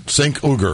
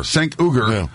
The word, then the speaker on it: Cenk Uygur: Is it Cenk Uygur? – Cenk Uygur. – Cenk Uygur, yeah.